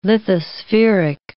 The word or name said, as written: lithospheric